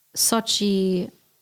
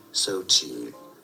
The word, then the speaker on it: Xochitl: Xochitl. – Xochitl. Nee, du hörst hinten noch ein L.